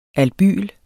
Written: albyl

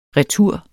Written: retur

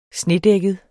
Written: snedækket

